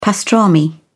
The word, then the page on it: pastrami: British English